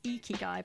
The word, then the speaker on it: Ikigai: Some people, when I say, because it's pronounced Ikigai, but a lot of people pronounce it Ikigai, but a lot of people think I'm saying like an Ikigai.